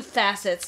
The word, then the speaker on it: facets: One of the faucets. I'm going to say it wrong every time I look at your face. One of the facets. – There you go.